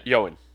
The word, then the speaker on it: Ioan: Is that how you pronounce? How do you? Yeah. How is that spelled? – Ioan. – It's like. Was that it? – Yeah. – I thought it was long. Okay, that's Yoen or Yoen.